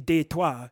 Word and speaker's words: Detroit: And actually, if you want to know the truth, we don't really pronounce Beloit correctly. It's actually a French word, Beloit, and it's similarly like Detroit, which is actually Détroit. And a lot of people don't know this. Obviously, we butcher it because we do the English pronunciation right.